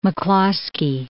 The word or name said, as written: McCloskey